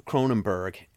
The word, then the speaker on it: Cronenberg: David Cronenberg, and I'm very proud of myself because I'm finally pronouncing his last name correctly.